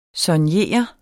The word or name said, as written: soignere